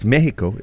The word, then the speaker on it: Mexico: Because we're told, say it like they say it, right? They yes. – say Mexico. Mm-hmm. So it's Mexico in the iconic Azteca Stadium.